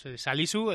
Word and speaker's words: Salisu: Que costó saber cómo era la pronunciación exacta, incluso hablando con el jugador, pero es Salisu, lógicamente hoy ha sido protagonista.